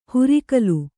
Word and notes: ಹುರಿಕಲು: Kannada; hurikalu

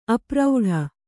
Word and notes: ಅಪ್ರೌಢ: kannada

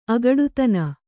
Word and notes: ಅಗಡುತನ: agaḍutana

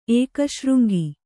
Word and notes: ಏಕಶೃಂಗಿ: ēkaśřŋgi

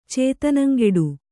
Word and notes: ಚೇತನಂಗೆಡು: Kannada

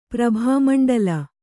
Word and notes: ಪ್ರಭಾಮಂಡಳ: prabhāta maṇḍaḷa

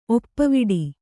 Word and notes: ಒಪ್ಪುವಡೆ: oppuvaḍe

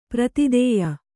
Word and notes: ಪ್ರತಿದೇಯ: prati dēya

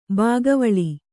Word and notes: ಬಗೆವೇಳ್: bagevēḷ

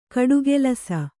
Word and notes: ಗಟ್ಟಿಕೆಲಸ: gaṭṭi kelasa

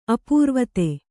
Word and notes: ಅಪೂರ್ವತೆ: apūrvate

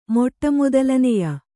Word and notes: ಮೊಟ್ಟಮೊದಲನೆಯ: Kannada

